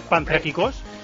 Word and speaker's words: Panthrakikos: No, 0-0 va con el Panthrakikos, que me ha dicho Pedrito que se dice así, Panthrakikos.